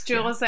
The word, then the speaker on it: Joules: Okay, so the Jules Athletic Warrior 100 miler, and am I saying that correct? J-O-U-L-E-S. Am I saying that correctly? – Yes. Jules Athletics.